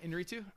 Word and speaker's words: Iñárritu: You said it right. – Did I? Iñárritu? I thought so.